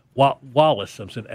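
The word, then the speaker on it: Wallis Simpson: This Willis Simpson, what she did. Willis Simpson. – Okay. – Or Wallace. Wallace Simpson. Wallis. Yeah. – That sounds right.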